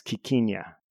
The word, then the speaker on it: quiquina: But yeah, it also looks like the pronunciation is quiquina. – Quiquina. Okay.